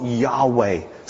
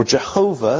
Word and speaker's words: Yahweh: Yahweh, the God, who makes promises and keeps them, the God who created, the God of the Old Testament, Yahweh, or Jehovah, as some would pronounce it, this is what he says.